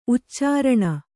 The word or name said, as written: ಉಚ್ಚಾರಣೆ